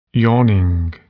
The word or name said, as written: yawning